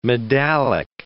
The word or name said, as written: medallic